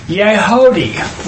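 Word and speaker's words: Yehudi (יהודי): You know what the word, the Hebrew word for Jew is? We're going to see Mordecai the Jew. Yeah? Okay. Yehudi. – Wait a minute. – It's three syllables.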